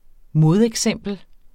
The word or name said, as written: modeksempel